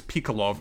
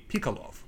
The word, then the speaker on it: Pikalov: Uh, uh, they, they, they, there's so many weird pronunciations, but I think it's Pikolov based on the real guy. Pikolov? Pikalov. P I K A L O V. Pikolov.